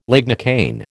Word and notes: lignocaine: American English

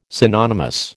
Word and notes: synonymous: American English

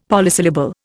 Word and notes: polysyllable: Indian

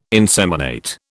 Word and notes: inseminate: British English

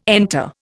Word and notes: enter: Indian

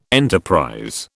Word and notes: enterprise: British English

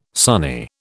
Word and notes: Sunni: British English

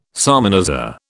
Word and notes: salmanazar: British English

